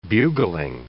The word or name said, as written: bugling